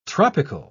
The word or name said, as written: tropical